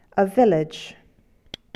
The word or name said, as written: village